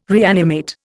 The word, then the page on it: reanimate: Indian